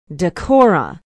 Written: dekorra

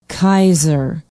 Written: keyeser

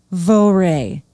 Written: voree

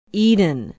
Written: eden

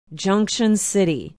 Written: junction city

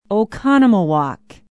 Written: Oconomowoc

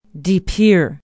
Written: de pere

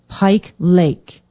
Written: pike lake

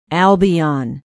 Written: albion

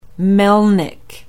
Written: melnik

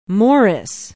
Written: morris

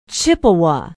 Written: Chippewa

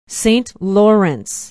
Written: Saint Lawrence

